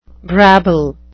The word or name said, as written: brabble